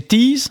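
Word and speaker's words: tiez: Ce pluriel, donc, c'est « tis », hein, et bon, qui a tendance, ici, on le prononce pratiquement « tir », parce qu'il a tendance à être influencé un peu par le Gwenédour dans notre région ici, dans le Pays Pline.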